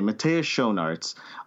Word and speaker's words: Matthias Schoenaerts: Can you guide me through it? Matthias Schoenaerts? – It's Matthias Schoenaerts, I think. – Okay, Matthias Schoenaerts.